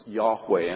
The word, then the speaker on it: Yahweh: Y-H-W-H, which we pronounce Yahweh. And 100 years ago, they would have thought was pronounced Jehovah.